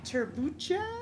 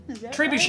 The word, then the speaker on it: trebuchet: It is a turbucha? – Trebuchet.